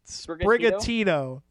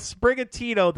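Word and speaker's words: Sprigatito: Spirigatito. Yeah, we'll go with Spirigatito, the grass cat Pokemon.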